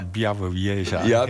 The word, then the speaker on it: Białowieża: Hij zei, ik moet naar Białowieża. – Ja, Białowieża. Dat L'tje met zo'n streepje spreek je uit als een W, heb ik geleerd, in Polen.